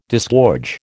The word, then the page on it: disgorge: American English